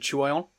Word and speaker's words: Nathan: I did inquire a Dutch friend for pronunciation for this, but it is simply just Nathan Chuon. So it's just Chuon, yeah.